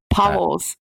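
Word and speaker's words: Pauwels: And he spoke about the incredible, these are his words, not mine, the incredible experience he had where Barry Pwels, is that how you say it? Anyone know? – Pwels.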